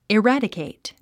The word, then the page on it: eradicate: irǽdəkèit; イラァディケェイト